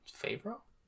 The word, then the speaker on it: Favreau: Favreau? – Yeah. That's how you say it.